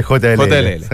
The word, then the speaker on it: JLL: Depende de donde estemos. – No, pero en Chile. – Acá en Chile, JLL.